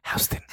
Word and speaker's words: Houston: Spell house. That's H O U S E. Take out the E, put a T O N. Houston.